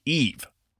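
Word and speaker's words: Yves: I mispronounced the name of Ubisoft's CEO, according to Kezia Neal at Dorkchild on Twitter. His first name is pronounced Eve.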